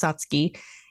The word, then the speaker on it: Satsuki: But like a lot of the Japanese words, it's pronounced a little bit differently than it's spelled. So it is pronounced Satsuki, and it means fifth month, which is generally when these azaleas bloom over in Japan.